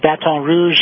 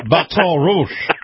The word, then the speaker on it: Baton Rouge: Well, that's right, and we do have a flood warning in the Baton Rouge area in Louisiana as well. – You said that right. – I hope so. – Baton Rouge.